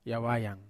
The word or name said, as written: awayang